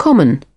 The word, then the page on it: common: British English